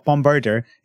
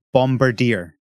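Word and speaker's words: Bombardier: No, you're pronouncing it wrong again. It's not Bombirdor. It's clearly Bombirdier.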